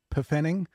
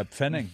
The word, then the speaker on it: Pfennig: Isn't it pronounced Pfennig? – Yeah, Pfennig. – Pfennig.